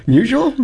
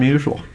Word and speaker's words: Musial: I would say Musial. – Yeah, Stan Musial.